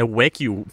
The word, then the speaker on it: Wagyu: Og du er jo ejer af Wagyu Bornholm. Jeg kunne forstå, det er sådan, man udtaler det.